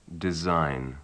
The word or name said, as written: design